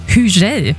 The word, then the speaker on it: Hugel: Wir haben uns schlau gemacht, wie spricht man ihn richtig aus, und die richtige Aussprache ist Hugel.